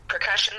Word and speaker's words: Prkushun: So percussion is how you pronounce it, but I'm perfectly happy with Perk because I'm also a happy, perky person.